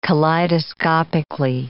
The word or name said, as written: kaleidoscopically